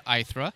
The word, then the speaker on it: Aithre: It's a little bit of an unusual name, but it's pronounced Aithre, and it's actually the feminine version of ether, I think, from Greek mythology.